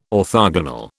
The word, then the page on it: orthogonal: British English